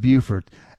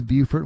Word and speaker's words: Beaufort: It's pronounced Beaufort. You know, I always get this wrong. There's a town in South Carolina and a town in North Carolina spelled the same. One's Beaufort and one's Beaufort. And I guess I got it wrong.